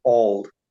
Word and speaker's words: Auld: A-U-L-D. Please give us your name and your beautiful accent. – So it's, I would pronounce it Auld. So Auld. Do you know the New Year's song, Auld Lang Syne? – I never made that connection.